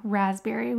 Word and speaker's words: raspberry: I think it's got to be raspberry with that silent P in there.